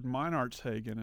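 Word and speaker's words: Meinertzhagen: That reminded me of Richard Meinartshagen.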